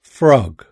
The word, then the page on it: frog: British English